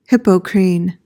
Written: Hippocrene

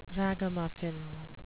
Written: ragamuffin

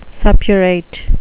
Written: suppurate

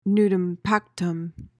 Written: nudum pactum

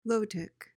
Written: lotic

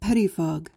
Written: pettifog

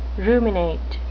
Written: ruminate

